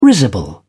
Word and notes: risible: British English

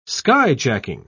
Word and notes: skyjacking: British English

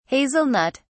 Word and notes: hazelnut (s): British English

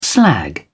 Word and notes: slag: British English

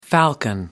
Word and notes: falcon: American English

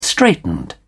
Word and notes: straitened: British English

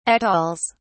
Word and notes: atolls: British English

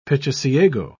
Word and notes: pichiciego: British English

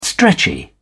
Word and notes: stretchy: British English

